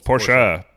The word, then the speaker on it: Porsche: Make sure you say it right. It's two syllables. – Porsche. Porsche is a two-syllable word.